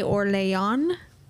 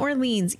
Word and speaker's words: Orléans: After the opera failed, the Marquise de Montesson, who was the wife of the de Orléans, not Orleans, even though it looks just like it. But this man on YouTube yelled at me telling me that that is not how you say it.